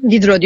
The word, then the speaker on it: Diderot: Diderot ya da Didro. Didro diye okunuyormuş.